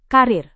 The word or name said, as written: karir